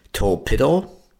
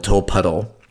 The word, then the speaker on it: Tolpuddle: But anyway, it's wrong. It's pronounced, apparently, we're told, the way that it's spelled, Tolpuddle.